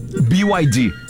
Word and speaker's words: BYD: BYD. – Que não pude deixar passar essa oportunidade. Faz questão de explicar que o correto é pronunciar... – BYD. – Falando o nome das letras em inglês.